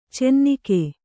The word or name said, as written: ಚೆನ್ನಿಗ